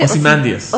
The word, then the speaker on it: Ozymandias: Ozymandias.